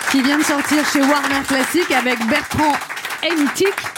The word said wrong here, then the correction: Haitink: Martha Argerich et Renaud Capuçon, c'est ça? – Haitink. – Oui, redites ça. Le prononcé. – Bernard Haitink.